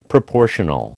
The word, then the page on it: proportional: American English